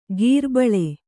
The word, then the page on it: ಗೀರ್ಬಳೆ: gīrbaḷe